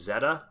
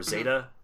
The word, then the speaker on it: Zeta: Like I said, before we started recording, I really like the character named Zeta, or Zeta, depending on how you want to pronounce it, spelled Z-E-T-A.